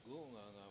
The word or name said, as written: gU7nganga7